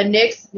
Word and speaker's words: Nyss: He's looking for a Nyx. Am I saying it right? The opposite of the ISN, the Nyx. – Yeah. – Yes, a Nyx male prostitute that services male clients.